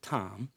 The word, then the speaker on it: Thom: Tom pointed out in this book, his name is T-H-O-M. It may be Thom, but I pronounce it Tom.